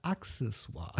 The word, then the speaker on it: Accessoire: Ich habe jetzt aber gelernt, es heißt Accessoire.